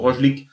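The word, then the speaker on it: Roglič: Roglic. Vom Tadej Pogacar. – Roglic sprechen sie jetzt auf Eurosport, wir haben es mal falsch ausgesprochen. Wird Roglic ausgesprochen, aber Roglic ist viel besser.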